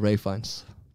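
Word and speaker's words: Ralph Fiennes: Det, jo, det skrives jo Ralph Fines, ja. men det uttales eh, Ray Fines.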